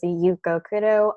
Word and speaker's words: Yuko Kudo: So again, my name is Yuko Kudo. It's spelled Y-U-K-O-K-U-D-O, short and sweet.